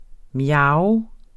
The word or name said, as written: mjav